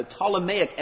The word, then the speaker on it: Ptolemaic: And that became known as the Ptolemaic. That's PT, by the way. The P is silent. The Ptolemaic Empire.